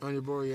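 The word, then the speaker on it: Onyeabor: I would assume it's Onyebor. – Onyebor, yeah.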